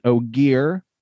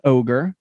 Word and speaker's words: Ogier: He is an Ogier, not an ogre.